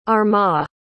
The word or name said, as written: armagh